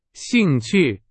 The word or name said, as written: 兴趣